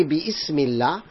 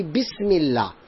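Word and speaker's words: bismillah (بسم الله): Like I don't say bi ismillah, I say bismillah.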